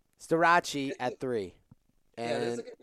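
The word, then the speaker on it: Starocci: Startucci at three and Labriola at four. – Wait, what did you say his name was? – Startucci? – Staracci. – Staracci.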